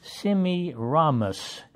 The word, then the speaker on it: Semiramis: His wife's name was, let me pronounce it halfway correctly, Semiramis. S-E-M-I-R-A-M-I-S.